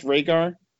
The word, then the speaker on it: Reagor: Is it pronounced Reagor? – Reagor, Jalen just Reagor.